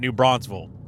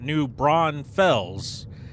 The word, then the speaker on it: New Braunfels: I, I I am terrible at pronouncing that town's name because I've always pronounced it New Bronzeville, and then I looked on the sign and it's spelt out New Braunfels, and I am 34 years old, and I have never said it that way, and I'm not going to change now.